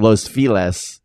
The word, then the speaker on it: Los Feliz: Or Los oh, Feliz. Yeah. Everyone yeah. here yes. pronounces it Los Feliz, yeah. right? – Oh, I know. – But I pronounce it Los Feliz.